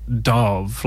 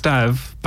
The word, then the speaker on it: Dav: the Americans just kept on saying Dove, like Dove, and I was just like, no, it's Dav.